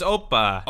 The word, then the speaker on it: Opa: It's opa. – Opa. – Emphasis is on syllable opa. – One. – On the O, okay, yeah.